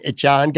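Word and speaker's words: Achane: That Devon Achan guy, Achan, I want to make sure, because he changed the pronunciation of his name. He goes out, he scores 4 touchdowns and says that he wants his name pronounced differently. It's Achan, not Achan.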